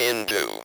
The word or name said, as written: into